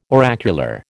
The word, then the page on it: oracular: American English